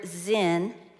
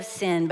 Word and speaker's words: Sin: You actually pronounce this word zin instead of sin, but I do think it's a good picture of what happens.